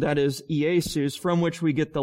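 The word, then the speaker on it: Iesus (Ἰησοῦς): And so in the Greek, the name Jesus is pronounced like Iesus. All right? It's pronounced Iesus in the Greek.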